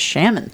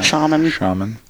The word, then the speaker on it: shaman: There was a shaman in that one. – That's not how you pronounce that. – Shaman.